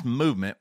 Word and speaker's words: MVMT: MVMT watches, and it's pronounced movement, was founded on the belief that style shouldn't break the bank.